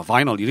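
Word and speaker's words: vinyl: 아, 비닐이 내가 발음이 잘못됐구나. 바이널. 이래도 상관없, 그래도 안 돼요.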